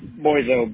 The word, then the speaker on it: Boyzo: Boyzo, B-O-Y-Z-O. – B-O-Y-Z-O.